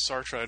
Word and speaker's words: Sartre: But right, and my default is always Sartre. And really, the truth is, from my understanding, it is somewhere in between, with that second syllable being kind of a vocalization more than a sound.